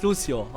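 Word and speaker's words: Lúcio: Wie spricht man das eigentlich richtig aus? Das heißt Lucio, oder? Weil ich meine, der Typ, der Lucio spricht, sagt auch Lucio und das ist ja Brasilianer.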